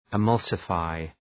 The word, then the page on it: emulsify: ı’mʌlsə,faı